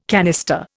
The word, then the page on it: canister: Indian